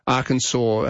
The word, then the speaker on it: Arkansas: But I think that's the difference, that Arkansas has adopted the French pronunciation in the same way that New Orleans has, as opposed to New Orleans, as we tend to say it. But Kansas is the English pronunciation.